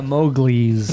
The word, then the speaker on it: Mowgli: But it's spelled M-O-W. Uh-huh. – Mo. – G-L-I apostrophe S. – Glee. – Glee. – Yeah, Mowgli's. – Like mow in the lawn and then Glee.